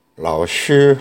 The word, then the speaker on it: 老师: 자, 여기서 주의하실 점은 요 라오시 할때쉬 발음이 조금 어렵습니다. 우리나라 발음에는 약간 없는 건데요. 스, 쉬, 약간 이빨 사이로 발음이 새어 나오듯이 이렇게 발음해야 됩니다.